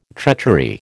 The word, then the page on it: treachery: American English